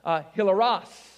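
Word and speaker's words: hilaros (ἱλαρός): If you say it actually with the Greek pronunciation, it's more like hilaros, all right?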